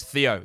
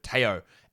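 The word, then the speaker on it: Théo: And I call him Theo Maledon because so many people mispronounce his name as Theo instead of Teo.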